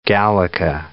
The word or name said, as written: gallica